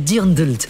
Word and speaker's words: Dirndl: Et chez les filles, c'est le Dirndl. Et je vous promets que c'est comme ça qu'on dit. Hein. Ça s'écrit D-I-R-N-D-L. Et regardez, c'est ce que me dit mon ordinateur. – D-I-R-N-D-L. Ah oui, Dirndl.